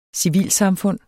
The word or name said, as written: civilsamfund